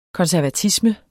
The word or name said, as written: konservatisme